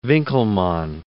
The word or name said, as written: Winckelmann